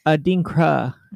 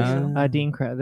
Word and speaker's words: Adinkra: You said in Dinka, right? – Oh, yeah. No. I think I butchered it. Yeah, she butchered it. It's Adinkra is what it is. Adinkra.